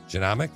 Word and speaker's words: genomic: How do you say genomic? G E nomic. Genomic. – I think genomic. – Genomic. Thank you. That's what I meant to say.